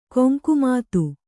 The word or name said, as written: ಗಂಗೆಮತ